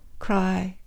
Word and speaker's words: chrysanthemum: Chrysanthemum, with that first silent H.